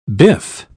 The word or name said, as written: biff